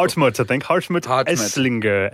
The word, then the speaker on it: Hartmut Esslinger: Yeah, and it appears that this guy, Harmut Esslinger, sorry, my pronunciation's awful. – Hartmut Esslinger. Esslinger, yeah.